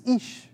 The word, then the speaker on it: Ish: The name of God for today is Ish, and yes, the pronunciation is like double E, Ish.